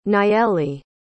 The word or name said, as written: nayeli